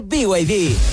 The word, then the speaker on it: BYD: Se escribe BID, pero se dice BYD.